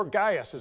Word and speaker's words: Gaius: The letter is addressed to Gaius, or Gaius, as it would have been pronounced in those days.